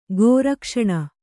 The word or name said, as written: ಗೋರಕ್ಷಣ